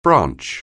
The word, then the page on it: branch: anglais